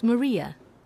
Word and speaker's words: Maria: It's Maria Badajoz. – How do you spell that? – My first name's Maria. M-A-R-I-A. – And your last name? – Badajoz. That's B-A-D-A-J-O-Z.